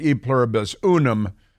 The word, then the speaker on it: e pluribus unum: And then I believe it's pronounced e pluribus unum, but I didn't go to Harvard.